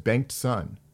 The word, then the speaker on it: Bengtson: Well, it's just Bengtson.